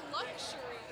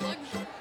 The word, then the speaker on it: luxury: It's not luxury. – There's an X in there, is there not? – Yeah, but it's pronounced luxury.